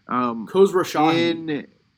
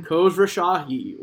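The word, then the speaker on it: Khosrowshahi: Khosrow Shahi. – Say it again. – Khosrow Shahi.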